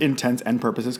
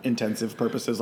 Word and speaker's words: intents and purposes: I'm glad that you said intents and purposes, because that's how it's pronounced, not intensive purposes.